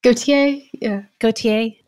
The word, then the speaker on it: Gauthier: And did I say her name right? Gauthier? – Gauthier.